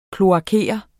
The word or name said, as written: kloakere